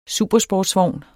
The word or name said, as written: supersportsvogn